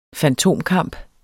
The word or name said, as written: fantomkamp